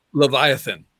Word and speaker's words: Leviathan: Leviathan.